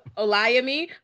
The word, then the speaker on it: Olayemi: I realized that I never introduced or told anybody how to pronounce my name right. And I was like, you know what? Let me say this before this mispronunciation of it sticks, but it's a Olayemi, but you can call me Olay.